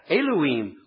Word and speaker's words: Elohim: God comes from the Hebrew word Elohim, which if you want to put this in your notes, it's spelled E-L-O-H-I-M, Elohim.